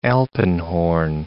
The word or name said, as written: alpenhorn